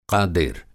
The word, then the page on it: क़ादिर: قادِر